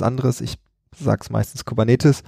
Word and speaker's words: Kubernetes: Kubernetes ist ein griechisches Wort. Ich habe damals in der Schule gelernt, äh, Altgriechisch und äh, Latein spricht man so aus, wie man es im Deutschen auch machen würde, weil niemand mehr weiß, wie man es damals ausgesprochen hat. Deswegen sage ich Kubernetes.